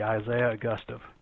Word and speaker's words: Augustov: Augustov, like Augusta National. Huh. So anytime you think about saying it, just think of Augusta National and add a V, Isaiah Augustov.